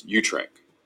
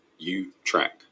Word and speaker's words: Utrecht: Now in the Dutch, it might sound a little bit different, but when you pronounce this in English, it's Utrecht. Utrecht.